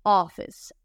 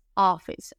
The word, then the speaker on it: office: Lo que ocurre aquí es que la CE final, ¿cómo se escribe oficina? O f dos Fs, y CE, pero esa CE final suena como S, office, office, office, acento en la O, ¿ok?